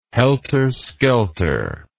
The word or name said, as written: helterskelter